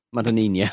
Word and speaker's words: Madonnina: Imagine Madonna, but with Ina at the end. Madonnina.